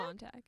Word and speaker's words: Montag: Montag. – That's how we're pronouncing it.